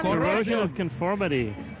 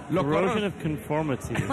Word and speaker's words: Corrosion of Conformity: Corrosion of Conformity.